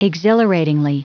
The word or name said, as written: exhilaratingly